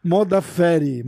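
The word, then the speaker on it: Modafferi: Bom, próxima luta: Roxane Motherfucker contra Tayla Santos. Modaferi, Modaferi, falei errado, desculpa.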